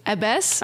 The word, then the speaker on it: abbess: Abbess? – Abbess. Abbess. Yes. Yeah. – I'd, I had not heard that word before. It's kind of a cool word. I mean, it's an abbey. It makes sense. Abbess.